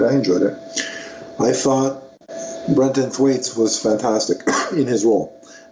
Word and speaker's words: Brenton Thwaites: and he will be played by actor Brenton Thwaites. Is that how you pronounce it? I'm pretty sure. – Sounds right to me, yeah.